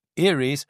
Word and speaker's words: Eyries: This band from Munich and Nuremberg, who pronounced their band name the American way, Eyries, are just a duo in the studio, namely Jonas Dannecker and Johannes Ludwig.